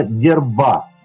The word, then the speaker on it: дерба: А, а название, в общем-то, происходит от слова «дерба» э, или «дербина», а вот именно Дерба, ударение а, да, на Дерба.